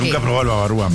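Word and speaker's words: Bavarois: Babarúa. – Babarúa. – Uh-huh. Babajúa sería. – Se escribe Babarois. – No sé, sí, pero sería Babarúa. – Babagua.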